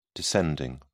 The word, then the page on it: descending: British English